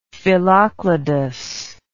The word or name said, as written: phyllocladous